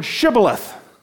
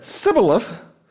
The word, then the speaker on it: Shibboleth: And if he said no, they'd say, 'Say the word Shibboleth.' But if he said Sibboleth, because he couldn't pronounce it correctly, they would take him and kill him.